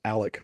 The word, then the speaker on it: Alex: spelled Alex Murdaugh, but it's actually pronounced Alec with like Alec Baldwin.